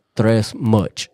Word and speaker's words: 3resmuch: At Tres Much. – How do you spell that? – The number 3. R-E-S-M-U-C-H.